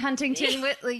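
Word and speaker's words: Rosie Huntington-Whiteley: Um, Rose Hudding I don't know how to say her name. Rose Huddington Whitley. Oh, Rosie She's- Huntington Whiteley.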